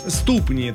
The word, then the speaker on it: ступни: Ступни. Да? Ну вот все-таки здесь ступни надо произносить на букву И ударение. Хоть и существительное, хоть и множественное число.